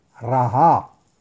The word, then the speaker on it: Raha: las letras Reish, Hei, Ain y se pronuncia Raha y que es el nombre de Dios número 39 de los 72 nombres.